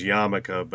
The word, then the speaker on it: yarmulke: They stick little pieces of paper in the crevices, but there's a picture of him with a, well, it's pronounced, I guess, yarmulke, but it's spelled like yarmulk.